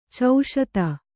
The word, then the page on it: ಚೌಶೀತಿ: kannada